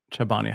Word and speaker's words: Chobani: Is it Chobani or Chobani? How do you say it? – Oh, I say Chobani.